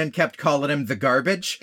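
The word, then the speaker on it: Garbitsch: I mean, to be fair, it's spelled G-A-R-B-I-T-S-C-H, I think. But it sounds like garbage.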